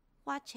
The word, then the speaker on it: watch: 要连音变成 watch and，记得哦，watch 的 t 是不发音的哦。